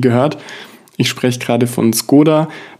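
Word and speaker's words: Škoda: Das wurde von Skoda selbst auch lange Zeit in Deutschland so kommuniziert, eigentlich heißen die aber anders, beziehungsweise es wird anders ausgesprochen. Und wenn du darauf achtest, mittlerweile in einigen Fernsehwerbungen oder eben Video-Ads, sagen sie auch Skoda zu sich selbst.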